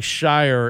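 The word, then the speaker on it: Scheyer: When you got a name like Shire and it rhymes with fire and you're replacing a legend, is this going to be bad? – I think so.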